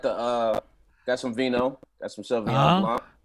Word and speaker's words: Sauvignon Blanc: Mark said it right. Sauvignon Blanc. – This. He said it with a French accent and shit.